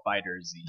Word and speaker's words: FighterZ: But the Z is attached to the fighter, but it's capitalized, so it's not like FighterZ, it's Fighters. – It's supposed to be pronounced Dragon Ball FighterZ.